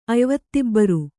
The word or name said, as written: ಅಯ್ವತ್ತಿಬ್ಬರು